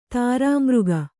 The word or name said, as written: ದುರ್ಮಾರ್ಗ